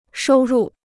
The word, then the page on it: 收入: shōu rù